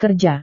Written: kerja

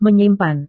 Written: menyimpan

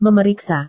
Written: memeriksa

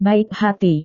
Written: baik hati

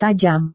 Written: tajam

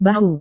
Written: bahu

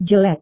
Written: jelek

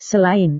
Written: selain

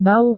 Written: bau

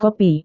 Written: kopi

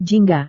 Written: jingga

